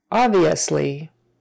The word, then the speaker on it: obviously: Obviously, obviously, that B is silent. The first O is a short O, ah, obviously, obviously. Don't squish it together too much. We want to hear the whole thing, obviously.